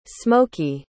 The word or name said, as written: smokey